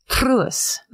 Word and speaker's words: Truus: So I'm just imitating a Dutch woman who's clearly, this is how you're supposed to say it. – Okay. – Truus.